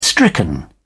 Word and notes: stricken: British English

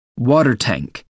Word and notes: water tank: American English

